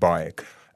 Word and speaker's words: BAIC: Of course, BAIC, the acronym, but I mean pronounced Bike.